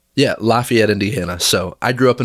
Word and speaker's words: Lafayette: So I've always had the pronunciation thing with my hometown. So not Versailles, Versailles, Lafayette. Yeah. – That's right. Yeah. Well, I was in Louisiana and it was Lafayette.